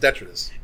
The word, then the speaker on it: detritus: Detritus. – That's true. Yeah, that did happen. – Okay. Yeah. Anyway, as you move through the Deatrice, the Detritus. It's pronounced Detritus.